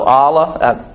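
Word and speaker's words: alah: But what was interesting to me, you know how you pronounce that word in Hebrew? Allah. I just thought that was interesting. It's probably coincidence, but that's the Hebrew word that's translated excretion, which means a curse. The Hebrew word, the way you say it, is Allah. And I looked up the Hebrew and the pronunciation, all that stuff, make sure I had this right. And then it said the A was pronounced just like fa, father. So Allah.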